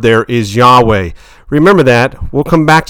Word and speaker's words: Yahweh: The actual language, though, it was a YHWH pronounced as Yahweh, or some say Yahweh.